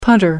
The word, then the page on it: punter: American English